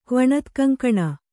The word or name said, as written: ಕ್ವಣತ್ಕಂಕಣ